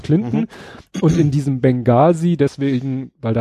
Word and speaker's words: Benghazi: Also es gab nämlich eine Geschichte in Benghazi und das wird im Englischen nämlich mit H gesprochen. – Mhm. äh, geschrieben, aber nicht gesprochen.